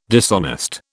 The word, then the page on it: dishonest: British English